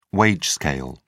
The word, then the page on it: wage scale: British English